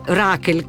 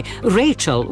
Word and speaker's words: Rachel: Sono invece nati in data 5 settembre l'ammiraglio Horatio Nelson, l'attrice Rachel, come la chiamavo io da bambina, ma si dovrebbe pronunciare Rachel Welch, lo storico meteorologo Edmondo Bernacca e la regista Cinzia T.H.